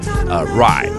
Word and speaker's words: Rhye: It's from the Canadian alternative R&B artist Rye. That's spelled R-H-Y-E, if you're wondering.